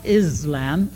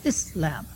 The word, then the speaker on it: Islam: Also, the name of the religion is not Islam, it's Islam.